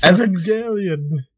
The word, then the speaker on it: Evangelion: Evangelion. I always thought of it like evangelism, almost like Evangelion, but the G is different. – Hard. It's a hard G.